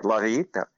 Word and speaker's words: La Villita: So anyway, that's how you say it, La Villita. It's a Spanish word.